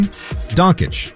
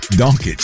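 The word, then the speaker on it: Dončić: You know, the Lakers do have Doncic, though, still. – Yeah. And what'd you call him? – Doncic. – Oh. Doncic.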